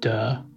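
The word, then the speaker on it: duh (德): So unfortunately, in modern Mandarin, it's pronounced duh, which my students sometimes giggle about.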